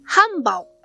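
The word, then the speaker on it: 漢堡: えー、漢字の漢に、保つという字の下に、土という字を書きます。これで、ハンバオと読むそうです。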